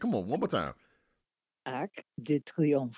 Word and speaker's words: Arc de Triomphe: Arc de Triomphe, which is Arch de Triomphe, which is the way Americans say it.